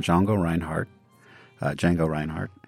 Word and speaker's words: Django Reinhardt: There was a guitarist named Django Reinhardt, French pronunciation,